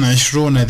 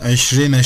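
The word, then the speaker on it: eşrine (عشرين): Sonra 22'nin 20 kısmına gelince, 20 kısmın için eşrune değil de eşrine şeklinde okundu sorusunun cevabı da şöyledir efendim.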